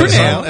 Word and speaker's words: Fresnel: Fresnel with a silent S, by the way, if you're keeping track. Fresnel. – It's spelled exactly as it sounds. – With an F, apostrophe, R-E-S-N-E-L.